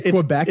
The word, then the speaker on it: Quebec: It's Quebec, right? You don't say Quebec. You don't pronounce the U.